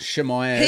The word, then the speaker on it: Chimaev: Chimaev? Is that how you pronounce his name? – Hamzat Shemaev.